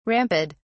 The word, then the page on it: ramped: British English